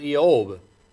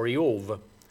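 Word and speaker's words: Eob (אִיּוֹב): if you say J-O-B, what else would you think? But recognize that in the Hebrew, it's Eob. Eob, or Eov.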